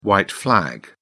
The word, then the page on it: white flag: British English